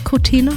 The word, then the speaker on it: Cortina: Is that how you say it, Cortina? – Yeah, absolutely. Yeah.